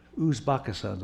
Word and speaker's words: Uzbekistan: U-Z-B-E-C-K-I-S-T-A-N. Uzbekistan, the way I was told to pronounce it.